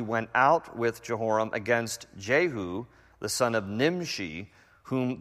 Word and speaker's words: Jehu: There's no J in the Hebrew alphabet. His name is pronounced Jehu.